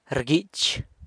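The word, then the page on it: rguich: San Lucas Quiaviní Zapotec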